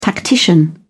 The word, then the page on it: tactician: British English